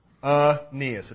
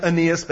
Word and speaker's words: Aeneas: And yes, that's how you pronounce that. Aeneas. It doesn't look like Aeneas, but it is Aeneas.